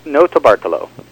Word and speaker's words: Notarbartolo: So Leonardo, I don't know how to pronounce it, Notarbartolo? – Pretty close. Notarbartolo. – Notarbartolo.